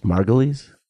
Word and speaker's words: Margulies: So it's Juliana Margulies, who is on the show. Gloria Allred. – I think it's Margulies.